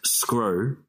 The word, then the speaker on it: Sgro: Um, so my last name is spelt is pronounced Scro, and it's spelled S G R O.